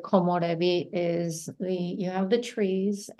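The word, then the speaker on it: 木漏れ日: Takuma, the word, and if please help me if I say it wrong, komorebi. – Yes.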